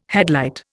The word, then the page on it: headlight: Indian